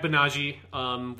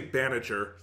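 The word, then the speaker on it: Banagher: But yeah, so in Unicorn Gundam, you had Banaji, which is not the way that it's spelled. It feels like a weird pronunciation in English, but that's just how it sounds. And any other pronunciation is so far away, I just can't say it. – You're not going to say Banager. – The character doesn't register to me if someone said Banager to me.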